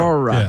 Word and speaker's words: Aurora: Aurora.